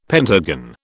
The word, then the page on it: pentagon: British English